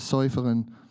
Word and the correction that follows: Seufzen: Seufzen, Seufzen, Seufzen, danke Peter, der aber die Herzen erfrischt, weiß, was äh, das Tragen des Geistes ist.